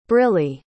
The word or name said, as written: Briley